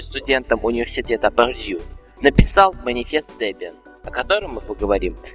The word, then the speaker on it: Debian: Поскольку многие люди об этом спрашивают, слово Debian произносится именно как Debian, хотя среди русскоязычного населения можно услышать и Debian.